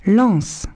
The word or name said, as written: lances